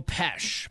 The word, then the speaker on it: Pesh: For me, my real name is Joe Pesh, but it's spelled, looks like pies. But for radio, so people can say my name correctly, Pesh? – That doesn't even make any freaking sense. You have the worst last name. – I have a weird Polish name. – It's horrible. – I was born into it, but I spell it for the radio, P-E-S-H.